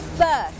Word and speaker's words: berth: berth berth berth อ่านออกเสียงเหมือนกันกับคำว่า birth birth